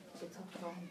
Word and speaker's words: Bitachon: In English, B-I-T-A-C-H-O-N. – Bitachon.